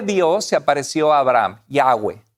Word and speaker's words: Yahweh: Y la mayoría de los expertos bíblicos nos aconsejan como cristianos eh, pronunciar el nombre de Dios como Yahweh. Entonces, Yahweh sería el nombre más correcto.